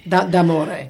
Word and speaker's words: d'amore: L'ultimo Notte di Amore. – D'amore. – Not too bad.